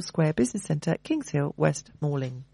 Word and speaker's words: West Malling: Okay, so there's a workshop at Churchill Square Business Centre in Kings Hill in West Malling.